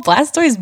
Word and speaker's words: Blastoise: The Blastios? – I'm sorry. Did you just call Blastoise Blastios? – I wasn't sure.